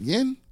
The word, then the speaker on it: yen: It's kind of pronounced the same, but it's yuan, yuan is the Chinese, and yen, yen is the Japanese.